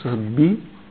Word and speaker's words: съдби: Значи в Библията, като срещнете думата съдби, удрайте едно ударение на съдби.